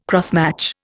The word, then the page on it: crossmatch: Indian